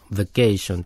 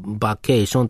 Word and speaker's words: vacation: ってことは、 ation だから、 vacation の a のところにあるときはちゃんと a って発音するけど、でも最初の va の後は vacation でしょ。日本語だと、例えば vacation ってはっきり、 ba とか言うとから、 v の後の a を vacation って発音すると思うけど、カタカナ言葉だと。英語だと ba って、あって発音しないのね。 vacation なのね。うんなのね。